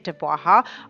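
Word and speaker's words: DABWAHA: Now, if you were curious, that is how you say it, Dubwaha.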